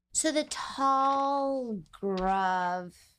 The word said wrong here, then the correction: Tazgrul: am I saying that correctly? The minotaurs, that particular... – Tazgrul. – The Tazgrul? Yes.